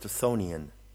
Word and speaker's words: Chthonian: Chthonian is pronounced Chthonian, and it refers to the original foundations of the planet Earth.